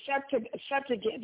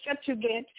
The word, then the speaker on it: Septuagint: So this translation of the Hebrew Bible into Greek came to be known as the, and I'm going to spell it for you, Septuagint, you are, and it's spelled Septuagint, it's spelled S-E-P-T-U-A-G-I-N-T.